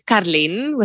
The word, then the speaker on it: Karlien: So why don't you say your name in the Afrikaans way so that people can hear it pronounced properly? – So my name is Karlien with a R.